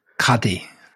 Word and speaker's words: Qadi: Is it a Qadi? A Qadi? Islamic judge? – Yeah. – Is it a Qadi? – Yeah. – You sure? – Yeah. – You're not bluffing? – Qadi. – Qadi.